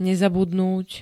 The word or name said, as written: nezabudnúť